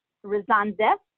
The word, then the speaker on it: Rezonzew: My name is Sarah, S-A-R-A-H, Rezondev, which is spelled R-E-Z-O-N-Z-E-W.